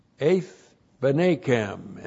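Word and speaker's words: Eith Benekem (את בניכם): There's the Aleph, E-T-H, the direct object marker, joined by a Makef, 2, then B, Shiva, N, long E, K, E, M. Eith Benekem, and that's the sons, your sons.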